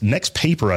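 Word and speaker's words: NXTPAPER: This next paper, I think, is how it's pronounced. 14 Probably. – Pro. – Next paper.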